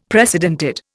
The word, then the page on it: precedented: Indian